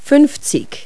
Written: fifty

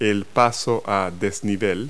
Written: overpass

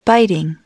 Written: biting

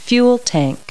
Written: fuel tank

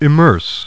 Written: immerse

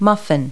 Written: muffin

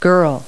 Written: girl

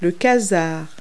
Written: quasar

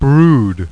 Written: BROOD

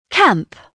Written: camp

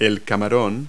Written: shrimp

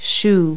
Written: shoe

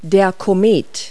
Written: comet

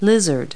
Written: lizard

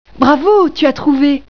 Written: bravo

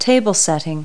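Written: table setting

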